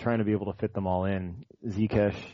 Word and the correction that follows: Ziyech: is that how you pronounce it? – Ziyech, thank you.